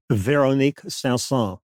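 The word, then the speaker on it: Véronique Sanson: Diana Ross, you know, tell me if I say this wrong. Veronique Sanson. Veronique Sanson. Oh, there you go.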